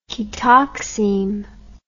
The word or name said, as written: ketoxime